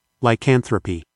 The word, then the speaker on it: lycanthropy: Lycanthropy.